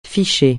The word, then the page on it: ficher: francais